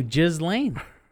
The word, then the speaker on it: Ghislaine: I'm telling you, Ghislaine. Right. Jizz, like the word jizz.